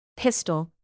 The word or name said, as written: pistol